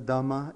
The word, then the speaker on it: Dhamma: Refuge in the Dhamma is, how we pronounce it in the Theravada tradition, which is what we practice here. In the Sanskrit, Mahayana, we call it Dharma.